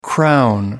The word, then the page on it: crown: American English